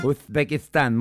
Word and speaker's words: Uzbekistán: Uzbekistán. Uzbekistán. ¿Así? – Uzbekistán, muy bien. Sí, claro, con la Z. Tienes que pronunciar la Z.